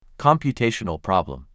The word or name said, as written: Computational problem